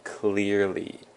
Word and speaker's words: clearly: The next word is clearly. Clearly, with the emphasis on the first syllable.